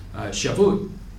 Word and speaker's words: Shavuot: And then the Festival of Weeks, Shavuot, is the way it'd be pronounced, that's weeks in Hebrew, was so named because it took place 7 weeks after the first barley harvest.